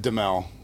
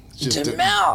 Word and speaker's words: Duhamel: Well, if you're in France, I think it's Duhamel, but we've always said Duhamel. – Duhamel.